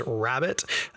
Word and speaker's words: rarebit: Genuinely delicious uh, uh, dish, pronounced by most English speakers as rabbit, um, but spelled R A R E B I T, rarebit.